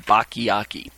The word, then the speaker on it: Bacchiocchi: His name is spelled capital B-A-C-C-H-I-O-C-C-H-I, Bachiaki. All right.